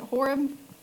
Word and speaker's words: Horeb: I think that's how you say, is it Horeb? Yep, Henry's nodding yes.